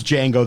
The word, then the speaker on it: Django: The hero's Django, the J is silent, of course, who not only collects bounties on whiteheads, but also looks to rescue his slave wife, Broomhilda.